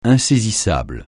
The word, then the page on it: insaisissable: francais